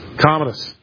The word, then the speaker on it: Commodus: I don't know how to say his name. I feel like I want to say Commodus. Don't know much about the guy. It's Commodus? – Commodus.